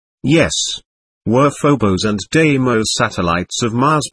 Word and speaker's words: Deimos: I believe the ancient language term would be Deimos phonetically, Deimos.